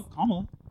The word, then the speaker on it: Kamala: Oh, Kamala. – Kamala? – Kamala? – Kamala? – Because like Kamala Harris.